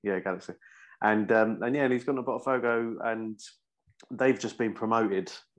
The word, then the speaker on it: Botafogo: Botafogo, yeah.